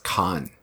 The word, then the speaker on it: Khavn: How do you spell that? – K-H-A-V-N. – Oh, and it's pronounced Khan.